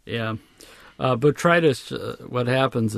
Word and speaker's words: botrytis: So I got online and was horrified to see that it looks like I have what they're calling botrytis. Is that how you say it? – Yes.